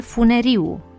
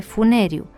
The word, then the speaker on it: Funeriu: Tot greșit s-a auzit frecvent pronunțarea ministrul funeriu în loc de funeriu, care provine din substantivul comun funer, variantă a lui funier, adică persoană care face sau și vinde funii.